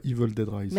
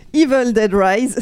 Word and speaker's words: Evil Dead Rise: Je sais que je le prononce pas bien. Quelqu'un peut le dire correctement à ma place Moi, je dirais Evil Dead Rise. – Merci. Evil Dead Rise, sorti en salle le 19 avril.